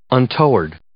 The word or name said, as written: untoward